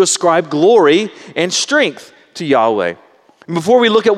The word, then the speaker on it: Yahweh: The problem with that is that pronunciation is really based off of a misunderstanding of the Hebrew language and some ancient manuscripts. So most scholars today think that we should actually pronounce the Tetragrammaton, God's name, these four letters, something like Yahweh.